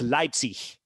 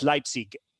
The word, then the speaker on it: Leipzig: I mean, in most of Germany, it's Leipzig. In some parts of Germany, it's Leipzig with a hard G, you know?